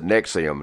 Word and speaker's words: NXIVM: He's been charged with running a violent slave cult called NXIVM, they pronounce it Nexium, not to be confused with the heartburn medication.